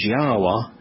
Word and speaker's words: Yahweh: And so the first challenge is to properly transliterate it, to properly convey its pronunciation as Yahweh, and the second is to understand the meaning of that name, the basis of the name, the purpose of the name.